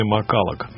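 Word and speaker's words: McCulloch: В 3-м и 4-м часах будем беспредельничать, а первые 2 часа мы сегодня посвящаем музыканту, которого зовут Джимми Маккаллок. Именно так правильно вроде бы произносится его фамилия.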